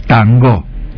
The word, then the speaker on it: tango: Ellos la pronunciaban como palabra aguda, tango.